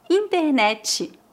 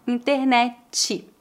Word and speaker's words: internet: Internet. Veja que aqui, novamente, adicionamos o som do I ao final e o T é pronunciado como TCH. Internet.